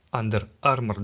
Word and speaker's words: Under Armour: Under Armour дословно переводится как «под броней» и произносится как Under Armour.